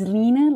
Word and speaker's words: Line: So my name is Line, like line, L-I-N-E. So that's my Danish name. It's pronounced more like Lina, but it is Line. And my last name is Morkbak, M-O-R-K-B-A-K.